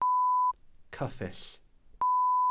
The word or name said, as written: cyffyll